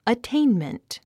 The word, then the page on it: attainment: ətéinmənt; アッテインメント